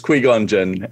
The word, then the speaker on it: Qui-Gon Jinn: It's Qui-Gon Jinn.